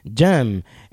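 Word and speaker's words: Dżem: El nombre de la banda proviene de la palabra inglesa jam session, que tiene la misma pronunciación que la palabra polaca para jam, jam, en el sentido de una fruta en conserva.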